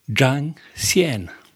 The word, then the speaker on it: Zhang Xian: Is it Xiang Xian? – Zhang Xian. – Gang Xian. – You weren't close, Kathleen. – No, I was never so mile off. – Zhang Xian.